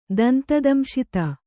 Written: ದಂತದಂಶಿತ

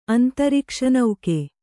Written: ಅಂತರಿಕ್ಷನೌಕೆ

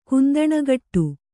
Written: ಕುಂದಣಗಟ್ಟು

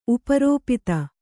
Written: ಉಪರೋಪಿತ

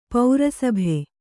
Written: ಪ್ರಸಭ

